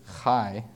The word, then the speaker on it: chai: you pronounce it chai with a little bit of throat in the beginning, and it's spelled C-H-A-I or C-H-A-Y.